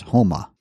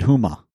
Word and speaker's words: Homa: He said, I hate to sound like Matt from Washington State, but let Phil know that Homa is pronounced Homa. – Did I screw it up? – You said Huma.